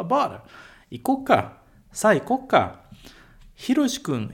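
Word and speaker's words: icoca (行こうか): É muito comum você escutar ikouka. Icoca, tá com o ts pequeno, tá bom? Então, icoca, na fala, quando a gente fala muito rápido, é comum você escutar sa icoca.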